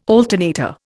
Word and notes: alternator: Indian

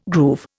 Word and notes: groove: Indian

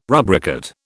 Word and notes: rubricate: British English

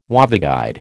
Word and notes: waveguide: American English

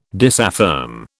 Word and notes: disaffirm: British English

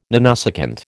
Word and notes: nanosecond: American English

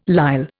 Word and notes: lisle: Indian

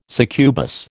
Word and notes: succubus: American English

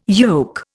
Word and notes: yoke: Indian

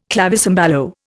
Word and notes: clavicembalo: Indian

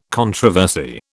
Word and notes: controversy: British English

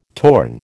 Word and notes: torn: American English

